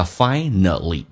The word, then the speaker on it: finally: Finally, 这个单词在美式发音当中跟英式不一样，英式发音叫 finally，美式发音叫 finally。因为当一个 a 碰到一个 l 的时候，在美式发音要被吸收，就是 a 不发音，所以它变成 finally。